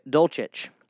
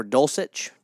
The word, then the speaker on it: Dulcich: Number six, Greg Dolchich, or Dulcich, depending on how you say it.